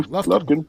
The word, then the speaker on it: Lufkin: Is that how it's pronounced? Lufkin? Lufkin? Sure. – Lufkin.